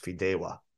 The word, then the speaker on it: Fideuà: But I did look that up online how to pronounce it, and as far as I could tell, it's pronounced Fideuà, Fideuà Barcelona, and it is also known as noodle paella.